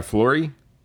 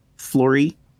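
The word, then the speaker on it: Florrie: Yeah, Flory. – Flory.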